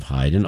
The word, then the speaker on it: Haydn: his parents did name him after Franz Joseph Haydn, although his name was pronounced Haydn by the family and everyone else, too.